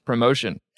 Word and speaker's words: ProMotion: Is it ProMotion or is it promotion? – Well, if there's a little bit of space between the O and the M, between pro and motion, then you're saying it right. So it is two words, perfect.